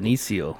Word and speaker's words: Benicio: It's Benicio.